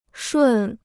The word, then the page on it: 顺: shùn